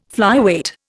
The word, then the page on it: flyweight: Indian